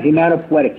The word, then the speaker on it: hematopoietic: Now, that's from hemopoietic. Is that the right way to say it? Hemopoietic? Or Hemat- hematopoietic. – He's hematopoietic.